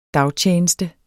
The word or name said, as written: dagtjeneste